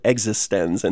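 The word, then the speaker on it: eXistenZ: It's actually in the film, they pronounce it Existence.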